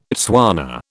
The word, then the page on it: Tswana: British English